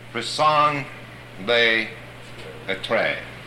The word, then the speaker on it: raison d'être: they are your raison d'être.